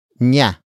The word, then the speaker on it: niya: The next word is nya. Nya. Nya. Nya is spelled as N I Y A.